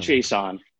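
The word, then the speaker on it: Chaisson: But what have you seen from CJ Henderson, the rookie cornerback, and Kalevon, how do you say Chiasson? – Chiasson? It's Kalevon Chiasson.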